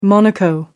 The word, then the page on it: monaco: American English